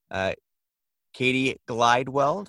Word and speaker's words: Cati Glidewell: I have with me Cati Glidewell.